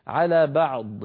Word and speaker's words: بعض: نبين سكون العين من مخرجها الصحيح ثم ننطق ضادا من غير ذيول على بعض بعض، ولا نقول بعض ضؤ، انظروا ضؤ هذا ضؤ لا يصح هذا الصوت الزائد في النهاية.